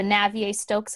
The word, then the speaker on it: Navier-Stokes: the Navier-Stokes equation.